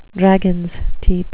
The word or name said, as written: dragons teeth